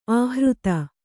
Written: ಆಹೃತಿ

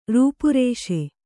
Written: ರೂಪರೇಷೆ

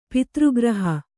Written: ಪಿತೃಗ್ರಹ

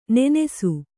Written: ನೆನಸು

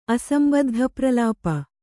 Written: ಅಸಂಬದ್ಧಪ್ರಲಾಪಿ

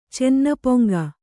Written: ಚೆನ್ನಪೊಂಗ

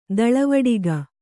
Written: ತಲೆವಡಿಕೆ

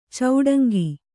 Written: ಚಿಟುಂಕು

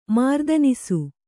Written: ಮಾರ್ದನಿಸು

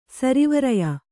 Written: ಸರಿವರೆಯ

